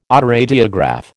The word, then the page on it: autoradiograph: American English